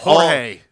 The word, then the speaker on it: Jorge: In Spanish, the G is pronounced with an H sound. – Right. Which is why Jorge.